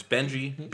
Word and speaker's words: Benji: But, like, my name's Benji, B-E-N-J-I.